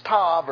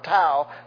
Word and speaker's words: Tav: which is Tav or Tau, depending on how you pronounce it.